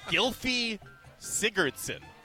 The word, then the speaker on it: Gylfi Sigurdsson: Gylfi Sigurdsson. Am I saying that right? – Oh, yeah, Icelandic midfielder.